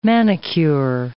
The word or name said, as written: manicure